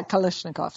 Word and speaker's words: Kalashnikovs: Kalashnikovs? – Yeah, Kalashnikovs.